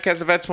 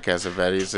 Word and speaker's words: Cassavetes: The most important thing to say about John Cassavetes is like, Cassavetes or Cassavetes? – I think say it either way. I think they pronounce it like his son. I think they pronounce it Cassavetes. – Cassavetes. – Yeah, because they're Italian.